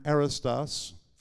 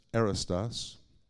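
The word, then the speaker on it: arrōstos: This is the Greek word arrestos. If you're taking notes, you spell it A-R-R-O-U-S-T-O-S, arrestos.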